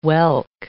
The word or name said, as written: whelk